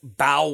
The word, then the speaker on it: Bao: You know, at Morimoto, they have something called Kids Bow Wow Meal, but Bow is spelled B-A-O.